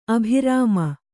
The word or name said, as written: ಅಭಿರಾಮ